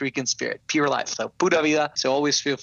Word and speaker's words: Pura Vida: Am I saying that right? Rally team. – Pura Vida. Yes.